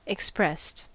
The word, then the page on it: expressed: American English